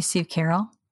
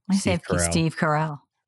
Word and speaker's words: Carell: Oh, you say Steve Carell? Steve Carell. – Yeah, okay. – I said it wrong.